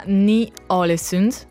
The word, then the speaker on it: Ny-Ålesund: Si chiama Ny-Ålesund, si scrive Ny-Ålesund, okay. Però si pronuncia con la O.